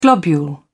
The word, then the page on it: globule: British English